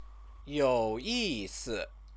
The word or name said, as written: youyisi